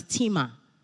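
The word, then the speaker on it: tima (τιμή): Honor in the Bible as a Greek word is pronounced as tima.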